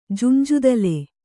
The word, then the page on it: ಜುಂಜುದಲೆ: Kannada